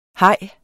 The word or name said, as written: haj